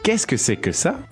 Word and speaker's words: Qu'est-ce que c'est que ça: Qu'est-ce que c'est que ça? – Okay, let's hear that dialogue three times.